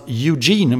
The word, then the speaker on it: Eugene: Det är ju världsmästerskap som vill börja till helgen här i någonting som ska uttalas Eugene, om jag förstår saken rätt.